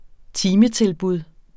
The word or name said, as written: timetilbud